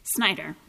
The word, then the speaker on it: Snyder: Is it pronounced Snyder? – Snyder. – Snyder. – Just pretend it's S-N-Y. – Good morning. – May it please the court. My name is Ruth Snyder.